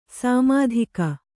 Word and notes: ಸಮುದ್ಗ: samudga

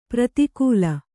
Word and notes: ಪ್ರತಿಕೂಲ: pratikūla

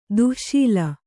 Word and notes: ದಾಶಾಳ: dāśāḷa

